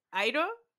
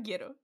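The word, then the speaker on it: Iero: ¿Airo? ¿Cómo lo dice? – Airo. Frank Airo.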